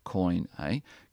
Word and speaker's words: koine: While all the known world spoke koine, it's pronounced koine,